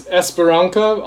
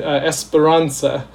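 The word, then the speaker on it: Esperança: It's Esperanca, although I've always said Esperanza. But I've been corrected by my friend Kimberly so many times that I don't think I ever get it right. – This is in Portuguese. – Yes, it's in Portuguese. As far as I know, it is Esperanca is the correct pronunciation, and it means hope.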